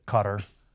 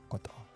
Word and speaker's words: Qatar: Qatar, don't pronounce it Qatar. In Arabic, there's actually two different sounds that aren't in the English language, and that's what makes it so hard to say. – Okay. – So the Q is more like a k type of noise, and the T is more of like, it's supposed to be more like a ba, but I can't really pronounce. I'd do more of a D, like a da. So it'd be like a kota, like a kota.